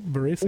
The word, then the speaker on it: Varese: You played in your first Fédération Internationale de Roller Sport Senior Women's World Inline Hockey Championship in Varese. Am I pronouncing that right? Varese? – Yep.